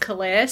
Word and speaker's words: calare: I think it's pronounced calare, C-A-L-A-R-E, which means to call out.